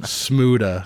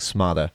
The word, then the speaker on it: Smuda: Smuda. – Smuda. I totally mispronounced it in my mind. I think I was saying smarter.